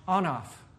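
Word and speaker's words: anaph (אנף): And it's pronounced anaph. Doesn't it sound like enough?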